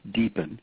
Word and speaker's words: DPN: or DPN, pronounced DEEPN, and usually referred to that way.